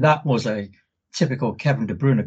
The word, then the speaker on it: De Bruyne: I was just going on YouTube and seeing an interview with him and finally learning how to pronounce this guy's name, Kevin De Bruyne. Not De Bruyne, as everyone says, De Bruyne.